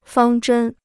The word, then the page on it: 方针: fāng zhēn